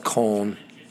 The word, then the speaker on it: Cologne: Some call it Cologne, some call it Cologne. It is spelled Cologne. It is said Cologne in English.